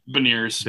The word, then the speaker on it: Beniers: First, Maddie Beniers from Michigan. I don't know if I pronounced that right. B-E-N. – Beniers. Beniers. Yeah, that's correct.